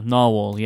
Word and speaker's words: Narwhal: Is it Narwhal? – Yeah, Narwhal, yeah.